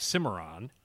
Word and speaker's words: Cimarron: And I love your description of this and the note that you sent me, because this is the 1982 Cadillac Cimarron.